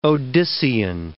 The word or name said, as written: Odyssean